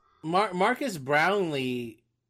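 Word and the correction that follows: Marques Brownlee: How do you say his name? – Marquez Brownlee. – Oh, sorry. Marquez Brownlee.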